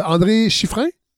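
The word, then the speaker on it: André Schiffrin: Euh, André Chiffrin oui. et Éric Eric Azon. Oui, exact. J'ai prononcé comme il faut? – tout à fait comme il faut.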